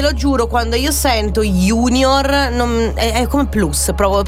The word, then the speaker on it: junior: Junior, scritto con la J. In realtà è sempre anche quello del latino, ragazzi, che vuol dire giovane, e si pronuncia junior ed è latino.